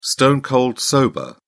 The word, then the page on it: stone cold sober: British English